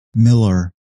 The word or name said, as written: Miller